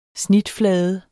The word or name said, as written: snitflade